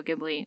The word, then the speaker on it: Ghibli: So he's the Japanese animation director of Studio Ghibli, or Ghibli, however you want to pronounce it.